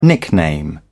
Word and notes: nickname: British English

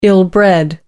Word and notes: ill-bred: American English

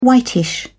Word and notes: whitish: British English

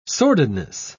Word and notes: sordidness: British English

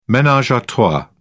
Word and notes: menage a trois: British English

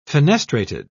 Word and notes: fenestrated: British English

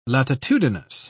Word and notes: latitudinous: British English

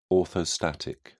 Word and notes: orthostatic: British English

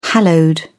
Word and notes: hallowed: British English

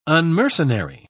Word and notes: unmercenary: British English

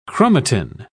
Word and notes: chromatin: British English

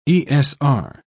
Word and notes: esr: British English

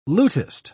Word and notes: lutist: British English

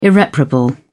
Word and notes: irreparable: British English

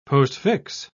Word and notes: postfix: British English

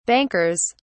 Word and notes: bankers: British English

